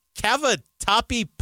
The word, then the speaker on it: cavatappi: Am I saying this right? Cavatappi pasta? – That's right.